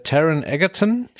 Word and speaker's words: Taron Egerton: Hm Hauptrolle, also Elton John, wird gespielt von äh, Taron Egerton.